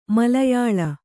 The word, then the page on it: ಮಲಯಾಳ: Kannada